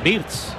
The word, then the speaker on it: Wirtz: Me encanta cómo lo pronuncias, pero creo que lo pronuncias mal. Porque dices Wirtz, que es Wirtz.